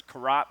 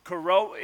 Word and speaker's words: Corot: At the time, I pronounced it Corot, but that's definitely not it. It's definitely Corot because I threw a Google on it.